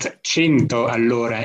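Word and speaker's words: Cento all'ora: So our funny name is pronounced 100 allora in Italian, right, and it means 100 kilometers per hour.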